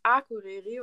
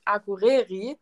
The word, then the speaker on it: Akureyri: Aber selbst wenn man kein Isländisch spricht, ist ja immer der eine Trick, dass man alles in Island auf der ersten Silbe betont. Von daher, selbst wenn du dann das R nicht rollst, immerhin hast du gesagt Akureyri und nicht Akureyri, wie das zum Beispiel dann auch viele machen. Ne? Also immer alles auf der ersten Silbe betonen, das ist schon die halbe Miete.